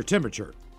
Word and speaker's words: temperature: Your temperature. – Temperature. Got to make sure to get that that other R in there.